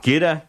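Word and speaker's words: Giuda: And Guda, in the set before that. – Judah. It's pronounced G-I-U-D-O. Italian, yeah.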